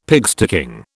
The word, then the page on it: pigsticking: British English